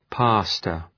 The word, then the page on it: pastor: ‘pæstər